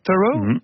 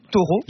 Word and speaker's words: Thoreau: Et il y a tout un mythe de la solitude, on parle beaucoup du philosophe qui s'appelle Henry David Thoreau. Thoreau. – J'ai jamais réussi à le prononcer, mais on a le droit de dire Thoreau, pas bien, comme un taureau.